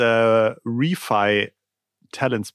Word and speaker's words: ReFi Talents: Ich habe gehört, ihr habt das äh, Refi Talents Programm an den Start gebracht. Habe ich das richtig ausgesprochen? Was ist das Refi Talents Programm? Absolut richtig.